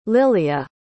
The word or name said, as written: liliah